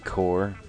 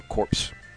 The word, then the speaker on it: Corps: After that, Rude Core, spelled C-O-R-P-S, or corpse, for those of you who prefer that pronunciation.